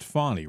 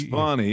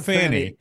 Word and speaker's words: Fani: I guess she pronounces it Fani, right? Is that what it's Fani, yeah. – Not Fanny. – But it's spelled Fanny, like the Bee Gees song. – Fanny is F-A-N-N-I-E, or is, I don't know. – Yeah. – But anyway. – Yeah, but hers is F-A-N-I.